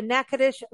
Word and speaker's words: Natchitoches: Natchitoches is the oldest settlement in the state of Louisiana, and I'm going to spell it out. N-A-T-C-H-I-T-O-C-H-E-S, Natchitoches. It doesn't sound how it's spelled, but you've got to go there. Don't let that spelling get you.